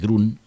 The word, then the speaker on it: Groen: Tipo QB Gruen, se escribe Groen, pero entiendo que se dice Grun,